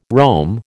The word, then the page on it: roam: American English